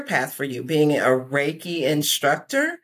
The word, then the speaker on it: Reiki: It's actually pronounced Reiki.